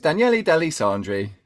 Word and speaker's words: Daniele De Lissandri: Have I said it right then? – Yeah, pretty much. So it's Daniele De Lissandri. Yeah, just kind of blend it all together.